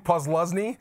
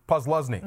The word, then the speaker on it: Posluszny: Posluszny. – What's that? – Posluszny.